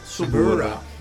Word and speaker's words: Suburra: Suburra.